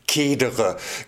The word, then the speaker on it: cedere: Das Verb von heute heißt cedere, gehen, schreiten oder weichen oder so. Das C von cedere spricht man dann spätestens seit dem Mittelalter wie Z und meist schreibt man das dann auch so.